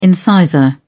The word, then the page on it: incisor: British English